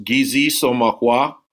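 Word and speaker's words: Giizis Makwa: Solar Bear pronounced Gizi Somakwa. In Ojibwe, Gizis meaning sun, Makwa meaning bear.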